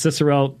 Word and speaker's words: Cicero: Cicero, or you've heard also pronounced Cicero, there's different ways of pronouncing Latin, uh, achieved compatibilism by limiting providence and rejecting divine foreknowledge.